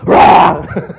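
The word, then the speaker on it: ra (רע): And the word wicked is the word ra. Ra. And so when you say it, you can say it like a dog barking.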